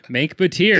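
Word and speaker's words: Mengke Bateer: Sorry, Menge Batir. – Menk. – Did I pronounce it wrong? – I thought we talked about this. – We did, but I thought I still had it right. – I said Menk like Frank.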